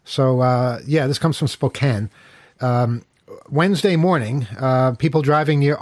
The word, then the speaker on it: Spokane: You hear the story, though, about the in Spokane. Did I say it right, Kyle? Spokane, right? – Yeah. – It's spelled Spokane, but you're not supposed to say it that way. You're supposed to say Spokane.